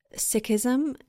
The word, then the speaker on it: Sikhism: Sikhism, and I might be mispronouncing that, so please correct me. – But, no, you got it.